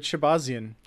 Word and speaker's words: Shahbazyan: Derek Brunson versus Edmen, I'm gonna butcher this last name, Shahbazyan? – That sounds good. Shahbazyan. I think we can agree to that.